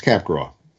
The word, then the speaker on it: Capgras: His name is Capgras, although it is spelled C-A-P-G-R-A-S.